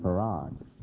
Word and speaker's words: Fard: He didn't tell them to call him Fard. He told him told his followers to call him Farad. So the spelling, the pronunciation of F-A-R-D is Farad, though it's not spelled that way. It's Farad.